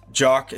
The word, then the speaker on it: Giac: Jock is spelled GIAC and then .42.